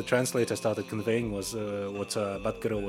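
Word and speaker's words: Batgerel: It's not Batgeri, it's Batgeril. Well, if you're Buryat Mongolian, you would say Batgeril. If you are Khalkha Mongolian, which Batgeril is, you would pronounce it as Batgeril.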